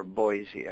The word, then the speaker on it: Boise: Can you talk to me briefly about the Mormon Church in Idaho or Boise? I understand it has a fairly significant influence in the community. – Sure. I love how you say about, but you also pronounce Boise correctly.